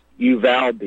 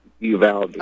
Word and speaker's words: Uvalde: I grew up in San Antonio, and my family's from South Texas, and the town is pronounced Uvalde. You know, if you're going to use the English dialect, Uvalde.